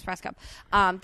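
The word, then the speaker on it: Rajskub: And Mary Lynn Raskub, is that how you say her name? – Yeah. – From 24? Yes, Raskub.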